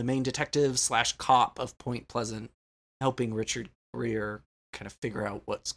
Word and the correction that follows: Gere: It's Richard Gere? – Greer. Ugh. It's one of those names I cannot say without saying it wrong. – It's G-E-R-E. Gere.